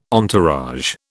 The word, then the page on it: entourage: British English